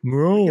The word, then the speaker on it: Muo: It is the Muo, M-U-O. I don't know how you say it. Meow, Muo, Muo. – It's Muo. – Muo? – I think so.